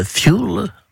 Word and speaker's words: fuel: D'abord, on prononce fuel, parce que ça vient de l'anglais, qui signifie combustible.